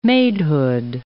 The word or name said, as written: maidhood